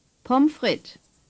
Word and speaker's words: Pommes frites: Pommes frites.